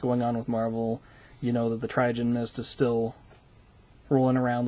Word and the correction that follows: Terrigen: The Terrigen Mist? – Terrigen Mist.